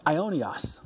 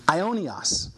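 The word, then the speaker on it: Ionios (αἰώνιος): It's pronounced Ionios. Ionios.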